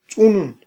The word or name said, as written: tz'unun